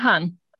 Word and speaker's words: Hahn: Ja, Herr Hahn.